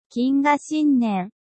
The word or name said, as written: kinga shinnen